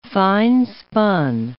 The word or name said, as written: finespun